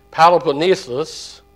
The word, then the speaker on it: Peloponnesus: In this case, it connected the Greek mainland with Peloponnesus. Well, I had to say that 1,000 times to say it right. Peloponnesus.